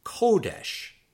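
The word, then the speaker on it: kodesh (קודש): Word 63, holiness, is kodesh, kodesh, kodesh. And the accent there is on the first syllable.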